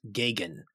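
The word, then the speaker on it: Geoghegan: And he said, it's pronounced Gagan. – Gagan. – Like Reagan.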